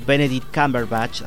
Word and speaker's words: Benedict Cumberbatch: Esta película del 2014, protagonizada por Benedict Cumberbatch, así se pronuncia, del director Morten Tildum, que no conozco mucho, y que si quieren buscarla, pueden encontrarla en Prime Video.